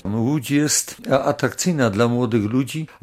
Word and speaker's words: Łódź: Jak to się właściwie wymawia? – Łódź.